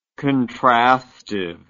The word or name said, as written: contrastive